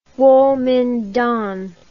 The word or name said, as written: Guomindang